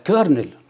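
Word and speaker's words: colonel: كنت بنطق عادي كولونيل. كولونيل. اطلع ببص على النطق. لا كيرنل.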